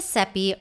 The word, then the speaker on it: Seppi: My name is Seppi, rhymes with Peppy, and I'm your host.